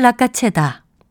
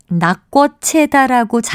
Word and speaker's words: 낚아채다: 낚아채다를 낙꽃채다라고 잘못 말하는 것은 아마도 발음이 편한 것을 따른 데서 나온 오류가 아닐까 싶습니다. 이 표현이 어떻게 구성된 것인지를 먼저 생각한다면 나 밑에 쌍기역 받침을 쓰는 낙가채다가 맞는다는 것을 확실하게 기억하실 수 있을 겁니다.